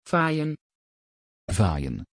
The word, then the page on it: vajén: Dutch